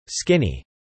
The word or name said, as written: skinny